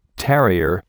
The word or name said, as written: terrier